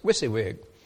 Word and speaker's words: WYSIWYG: The left thing there at the bottom is WYSIWYG. That's the way you pronounce it. WYSIWYG.